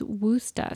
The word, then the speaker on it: Worcester: Now, I really want to call it Worcester or Worcester, but apparently that's how the people who aren't from there say it, which I'm not from there. But apparently the local pronunciation is Worcester.